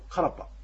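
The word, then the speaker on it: karpa: これは行ってきたよって言うんですけども、カラパというのは実は自分が行ってきたということで、クアラパというのがこのサルガー流域ではカラパというふうに発音されます。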